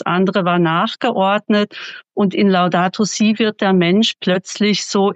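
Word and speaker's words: Laudato si': Also ich habe verstanden, obwohl ich es falsch ausgesprochen habe, Laudato Si heißt es, betont ja den Zusammenhang zwischen den ökologischen und sozialen Fragen.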